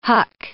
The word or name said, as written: huck